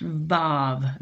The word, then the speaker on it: vav: That first word, an, it's a conjunction and it's pronounced vav, V-A-V.